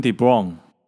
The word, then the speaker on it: De Bruyne: 结果丁丁是念 Kevin De Bruyne，有点像是这个布朗的这个 Brown，而不是不是有。